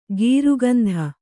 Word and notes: ಗೀರುಗಂಧ: gīru gandha

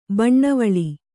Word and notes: ಬಣ್ಣವಳಿ: baṇṇavaḷi

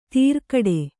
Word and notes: ತೀರ್ಗಡೆ: tīrgaḍe

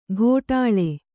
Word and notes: ಗೋಟಾಳೆ: gōṭāḷe

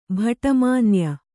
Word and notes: ಭಟ್ಟಮಾನ್ಯ: bhaṭṭa mānya

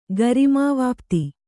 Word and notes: ಗರಿಮಾವಾಪ್ತಿ: kannada

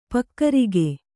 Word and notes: ಪೊಗರುಗು: Kannada

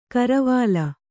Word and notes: ಕರವಾಲ: Kannada

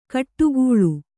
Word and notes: ಕಡುಗಾಳ: kaḍugāḷa

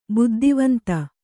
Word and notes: ಬುದ್ದಿವಂತ: buddivanta

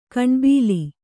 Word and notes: ಕಣ್ಬೀಲಿ: kaṇbīli

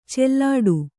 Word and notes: ಚೆಲ್ಲಾಡು: cellāḍu

